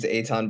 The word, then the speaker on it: Eytan: And so I am going to ask distinguished guests whose first name begins with the letter E, though it's pronounced with the letter A, to introduce himself. – What's cracking, Ben? My name's Eytan Boclin.